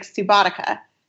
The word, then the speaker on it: Subotica: If you read Subotica out loud, it looks like Subotica, because the C isn't the same as in Latin. We would pronounce it like ca, but it's sa.